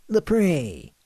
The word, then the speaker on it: Lapre: Lapre. – Can you spell that? – L-A-P-R-E.